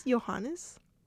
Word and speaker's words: Johannes: Johann. I'm probably saying it wrong. But anyways. – I think it's Johannes. – Oh, that sounds right.